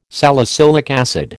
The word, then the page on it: salicylic acid: American English